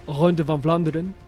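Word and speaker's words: Ronde van Vlaanderen: la Ronde van Flandren, ho he pronunciat bé? – Molt bé.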